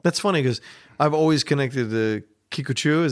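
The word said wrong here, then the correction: Kikuchiyo: No, it's Kikuchio. – Kikuchio, sorry.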